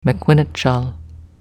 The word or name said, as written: Mequinnitchall